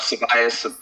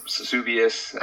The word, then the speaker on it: Subias: Subias, Subias.